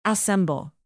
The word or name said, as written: assemble